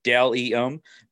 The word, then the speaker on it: Bdelliom: You can follow them on Facebook, Delium, B-D-E-L-L-I-O-M. Again, it's Delium, pronounced Del-E-M, B-D-E-L-L-I-O-M.